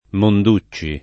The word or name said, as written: Monducci